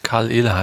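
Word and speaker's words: Caol Ila: Müsste eigentlich einfach Karl Ehle heißen. Karl Ehle. – Ja, Karl Ehle.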